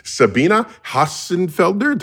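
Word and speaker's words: Sabine Hossenfelder: We have Sabina Hassenfelder. Do I pronounce that right, Sabina? – That was wonderful.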